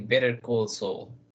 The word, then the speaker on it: Better Call Saul: Cuéntanos, Oscar, ¿qué nos ibas a platicar de Better Call Saul? si se pronuncia así? – Ajá, sí, suena raro igual traducido al español.